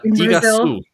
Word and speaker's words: Iguazu: Is it Iguazu? I don't know how to pronounce that. Do you know? – Iguazu. – Okay. Iguazu. – Yeah. – Iguazu. I don't know. There you go.